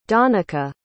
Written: donnica

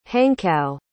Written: hankow

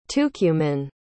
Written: tucumán

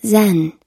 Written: zen

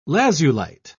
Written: lazulite

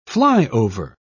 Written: flyover

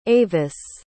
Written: avis

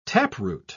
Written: taproot

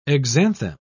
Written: exanthem

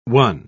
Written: one

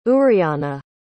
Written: uriana

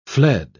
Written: fled

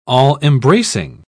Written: all-embracing

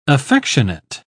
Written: affectionate

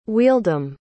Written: wheeldom

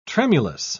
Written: tremulous

